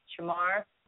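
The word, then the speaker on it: Jimar: It's Jamar Akaitza. I will spell it. because they're all weird, funky names, just like me, original. It's J-I-M-A-R, separate name, A-K-A-I-T-Z-A.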